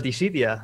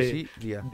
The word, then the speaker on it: Dissidia: Em, he estado jugando al, al Dissidia de la, de la Play, ¿lo has probado? ¿Cómo se escribe? D-S-S-I-D-A. sí. Dissidia.